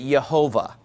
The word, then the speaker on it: Yehovah: Now, based upon the vowel markings that were put on that word in the Middle Ages, by Hebrew scribes, if you look at those vowel markings and you showed that to a Hebrew person who wasn't scared to pronounce it in modern Hebrew, then they would pronounce it Yehovah. That's how they'd pronounce that.